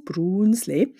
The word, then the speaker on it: Brunsli: Die schokoladehaltigen Brunsli, ausgesprochen mit langem U, Brunsli, werden nach ihrer Farbe benannt.